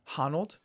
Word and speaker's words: Honnold: Honnold.